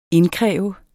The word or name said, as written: indkræve